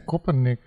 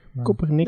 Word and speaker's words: Koppernik: Kopernik, hoe spreek je dat eigenlijk uit? – Kopernik. Kopernik. Dat zou ik ook denken, ja.